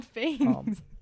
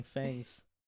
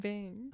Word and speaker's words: fangs: That's what I'm saying, fangs. – You said vang. – I said fangs. – Oh, sorry. Cut that out. – Yeah. Anywho, I had the fangs. Fangs. emphasis on the F.